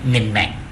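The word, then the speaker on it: Minh Mạng: มิงแหม่งนะฮะมิงแหม่งอ่านว่ามิงแหม่งแต่เวลาเราไปเห็นภาษาอังกฤษจะอ่านว่ามินมังนะจริงๆก็อ่านว่ามิงแหม่งนะฮะอันนี้ผมให้คนเวียดนามออกเสียงให้ฟังก็เลยจำได้ขึ้น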